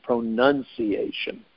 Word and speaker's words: pronunciation: All right, this is not French, but some people say pronunciation, because the word is pronounce, but pronunciation is pronunciation. Pronunciation.